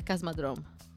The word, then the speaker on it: Kosmodrom: Io sono figlia e nipote di sovieti o sovietici, non so come si dica ad oggi, e ho chiesto conferma alla mia famiglia su questa pronuncia, perché di me stessa mi fido poco, ed è Cosmodrome.